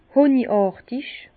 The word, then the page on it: hönigàrtig: Bas-Rhin; Herrlisheim